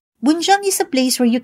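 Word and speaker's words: 번장: B-U-N-J-A-N-G. Bunjang. But Sir Jin said that in Korean, it is pronounced and spelled as Bunjang. – Usually, nagkakamali po yung pronunciation nila ng Bunjang kasi B-U-N yung spelling niya. Pero ang tunay na pronunciation po niya ay Bunjang.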